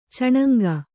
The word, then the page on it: ಚಣಂಗಿ: caṇaŋgi; kannada